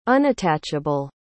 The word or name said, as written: unattachable